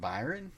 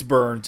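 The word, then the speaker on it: Byrne: how do you say his last name? Is it Byron? – I guess it's Burns. – I don't know. I've always said Byron. I'm asking. – No, it's Burns. It's John Burns.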